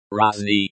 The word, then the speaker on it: разные: It's pronounced like, разные, stress on the first syllable.